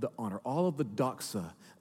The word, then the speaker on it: doxa: Here's the Greek word, doxa.